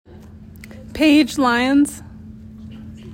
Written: Paige Lyons

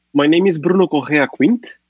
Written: Bruno Quint

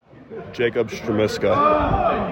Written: Jacob Strmiska

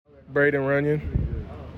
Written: Braydon Runion

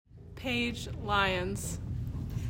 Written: Paige Lyons

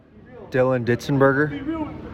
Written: Dylan Ditzenberger

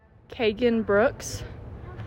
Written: Keagin Brooks

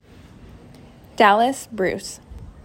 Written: Dalis Bruce

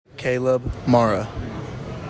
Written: Caleb Marra

